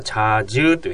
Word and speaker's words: 자주: 자 にうが入るから 자 주 というふうに発音します。